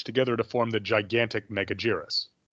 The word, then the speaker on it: Megaguirus: The Japanese, it always sounds more Megaguirus to me. That's the hard G instead of the soft G.